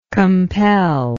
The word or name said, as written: compeller